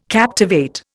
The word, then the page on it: captivate: Indian